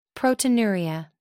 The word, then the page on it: proteinuria: American English